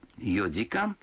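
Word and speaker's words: йодикан (四時間): Некоторые цифры в этих случаях произносятся не так, как обычно. 4 часа – это йодикан, 7 часов – ситидикан, а 9 часов – кудикан.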